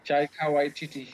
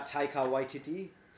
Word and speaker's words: Taika Waititi: Mr. Taika Waititi. – Yeah, Taika Waititi.